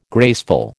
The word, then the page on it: graceful: American English